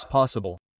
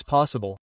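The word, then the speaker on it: posible: So as possible. The same is not possible, but pa pelota, but almost without the L. Well, like be okay. Possible.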